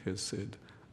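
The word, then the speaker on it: 헤세드 (חסד): 헤세드라는 단어는 헤세드, 케라고 발음이 될 수 있는 헤세드는 자비, 인자, 자비, 자비, 주님의 자비하심을 나타내며 밤마다 주님의 성실하심.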